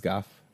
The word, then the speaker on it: Gogh: I've always been told Van Gogh. – Yeah. It's Goff. – Oh. Her history teacher pronounces it go. – Yeah, a lot of Americans do. – Oh, the in his language you pronounce it? I guess. Goff? – His state is worse.